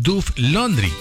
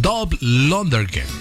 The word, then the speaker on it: Dolph Lundgren: Me dicen, se llama Duff Laundry. Y lo estaba buscando y no es Duff Laundry. Él se llama, es Dolph Lundgren. Dolph Lundgren. Lundgren, se escribe así.